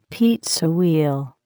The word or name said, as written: pizza wheel